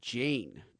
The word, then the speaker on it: Jain: It's a musician by the name of Jane, and it's spelled J-A-I-N.